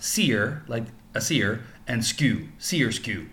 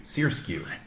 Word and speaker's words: SRSQ: And the band, the name that she's using right now is called, it's SRSQ, but it's pronounced Seer, like a seer, and SKU, Sérusier. – Sérusier.